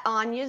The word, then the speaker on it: Áine: At Anya Zoo, because I run. – Spell that. – A I N E Z O O, baby.